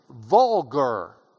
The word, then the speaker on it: vulgar: Common tongue in Latin, vulgar, is vulgar, is the way we pronounce the, the hard V.